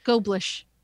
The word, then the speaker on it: Goblish: Shout out to Nick, the independent Goldblish? – Podcast. Yeah. – Goblish. – How do you say his name? – Goblish. Like goldfish.